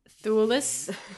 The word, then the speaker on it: Thewlis: I thought you pronounced his last name Thewlis. Like I thought the E was later in his name. Wow. I just learned something today. I thought it was Thewlis. Oh. Wow. It's not.